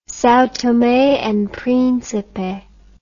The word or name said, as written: Sao Tome and Principe